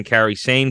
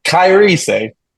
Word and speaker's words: Kairi Sane: And Did you just call her Kairi Sane? I'm going to need you to pronounce it correctly. You know Kairi how to say Sane. Kairi Sane. Come on.